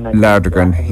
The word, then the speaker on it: Joakim Lagergren: But so on the third playoff hole against You'll have to pronounce the Swedish, his Joakim Lagergren, is it? – Lagergren.